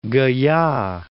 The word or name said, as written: Gaya